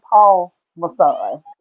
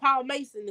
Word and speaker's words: Paul Masson: It's actually Paul Masson. – Okay. Because I was like, we gonna call it Paul Mason to see how many people correct us.